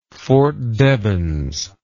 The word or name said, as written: Fort Devens